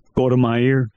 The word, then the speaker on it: Golda Meir: Golda Meir.